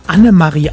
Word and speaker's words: Annemarie: Annemarie Eilfeld, so heißt sie richtig, nicht Maria.